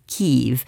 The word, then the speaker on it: Kyiv: because the pronunciation made by Ukrainians is Kyiv, and we've spent a long time in the English speaking news world learning to pronounce it. Kyiv's also spelled K-Y-I-V.